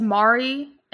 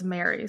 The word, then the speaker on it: Mary: I read it as Mari, and then Michael said it was Mary. So I was like, oh, okay, it's Mary. Yeah.